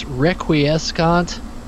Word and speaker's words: Requiescant: I never know how to pronounce this properly. I believe it's Requiescant.